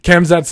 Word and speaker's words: Khamzat: This has been Kaz, I, I, keep mispronouncing his name. I want to say Kazmat. Khamzats, this is his third fight in, I think, 66 days.